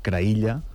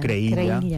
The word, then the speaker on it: Creïlla: No, que és Creïlla. – Creïlla. – O Creïlla, pronunciat a la valenciana.